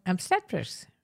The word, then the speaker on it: obstetrics: How do you say that? – Obstetrics.